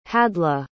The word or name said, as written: hadleigh